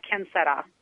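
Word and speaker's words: quién será: So sway is the English word, but I also have a verse I do in Spanish, quien será.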